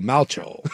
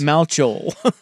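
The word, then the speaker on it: Malchow: It's pronounced like the know. Replacement's quarterback, Shane Falco, so Malcho. I guess I can't get over the way it looks. – Malcho. – Because it's M-A-L-C-H-O-W.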